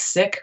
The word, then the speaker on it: Sikh: All I know is you said it right. It's Sikh, which goes against all my impulses. I want to say Sikh.